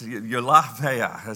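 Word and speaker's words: eulabeia: All right, so sorry about the pronunciation, eulabia, you know, opa, no, it's eulabia.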